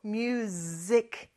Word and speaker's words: music: 참고로 뮤직이 아니고 뮤직. 이렇게 S는 Z 발음을 해주셔야 돼요.